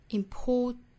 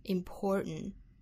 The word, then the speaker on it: important: important。美音的话呢，更倾向于发一个单纯的鼻音 important，也就是这个 t 的发音是有可能听不到了的。当然我们也可以选择把它的音发出来哈， important 也是可以的。